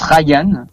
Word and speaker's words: Ryan: Alors ça se prononce Ryan. – Ouais. – C'est vrai que souvent on peut faire la distinction comme ça, parce que moi mon prénom c'est Ryan